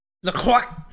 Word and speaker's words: LaCroix: LaCroix!